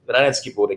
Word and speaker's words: Reinheitsgebot: So is the Reinheitsgebot, which is the purity law that you're referring to, and correct my pronunciation, if you like. – Reinheitsgebot, exactly, yeah, that's it.